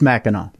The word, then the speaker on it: Mackinac: And then Mackinac Island or Mackinac Island. I'm not sure. What's the proper way to say that? – It's Mackinac, yeah.